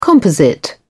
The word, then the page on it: composite: British English